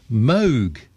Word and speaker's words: Moog: The ever-observant Dave has just let me know that what I've been calling a Moog synthesizer for decades should be correctly pronounced Moog, as in Vogue, even though it's spelt M-O-O-G. The correct pronunciation is Moog synthesizer.